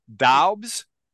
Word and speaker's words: Doubs: So D-A-U, give me this again. – D-O-U-B-S. Daubs. – Daubs. – I don't even know how to say it. – It looks like it's missing an E. It should have a silent E.